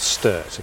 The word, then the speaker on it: Steart: It's called Sturt, okay, so it's... – Well, I might be pronouncing it wrong too. – No, I think you're right, that's what the sat-nav said.